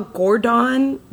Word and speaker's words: Gordawn: I spell Gordon, G-O-R-D-A-W-N.